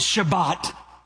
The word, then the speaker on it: Shabbat: You pronounce it Shabbat.